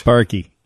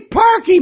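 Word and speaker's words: Parkey: Well, let's say his name right. He deserves it. – Yeah. – Cody Parker missed the kick, and we lost. – Parky. – God damn it. Cody Parky missed the kick, and we lost.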